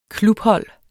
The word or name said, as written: klubhold